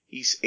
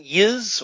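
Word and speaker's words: Ys: And by the way, when we say East, it's Y, capital Y, lowercase s. Some people would pronounce it Yiz, Y's, you know, but apparently it's supposed to be pronounced East.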